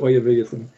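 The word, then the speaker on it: Oyaviggaton: It's a made-up word, right? You can pronounce it however you want. I pronounce it Oyavigathan.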